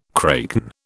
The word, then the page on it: kraken: British English